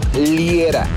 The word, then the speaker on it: Liera: Ahí me buscas como Fernando Liera, L-I-E-R-A,